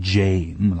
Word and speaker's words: Jame: That's where he got it, and you have to pronounce it Jame, like name, or he gets livid, even though it was a mistake at the hospital.